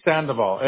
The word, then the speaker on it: Sandoval: Last name is Sandoval, S-A-N-D-O-V-A-L.